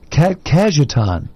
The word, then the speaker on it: Cajetan: Today, the church celebrates the feast of Saint, and you may have to help me out on pronunciation, Catajan? Cajetan. – Cajetan.